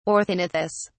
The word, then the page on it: orthognathous: British English